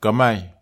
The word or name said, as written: kamai